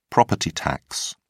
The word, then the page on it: property tax: British English